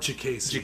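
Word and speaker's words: Diakiese: Mark Diax it's the it's Mark Jacasey. Jacasey, thank you. You're always good on that name.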